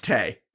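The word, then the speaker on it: Téa: Is it Tay or Taya, Leone? I think it's Tay. I think it's pronounced Tay. – The accent over the E. – Yeah, but I'm pretty sure I've heard it pronounced I think it's Tay. Yeah, I think it's Tay. – Tay Leone. Either way, it's Tay.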